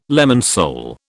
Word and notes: lemon sole: British English

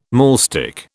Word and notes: maulstick: British English

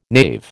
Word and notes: knave: American English

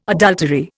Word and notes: adultery: Indian

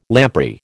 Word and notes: lamprey: American English

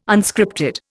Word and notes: Unscripted: Indian

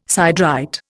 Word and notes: siderite: Indian

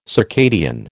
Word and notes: circadian: American English

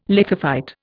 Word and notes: lithophyte: Indian

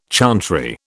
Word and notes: chantry: British English

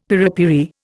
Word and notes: piripiri: Indian

